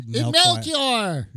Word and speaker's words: Melchior: How do you say that director's name? Melchior? – Melchior! – Melchior. – Oh. Melchior. Oh, it's French.